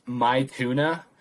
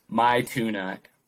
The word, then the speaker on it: Maithuna: The song is, it's pronounced My Tuna, but it's MAITHUNA, My Tuna, mm-hmm.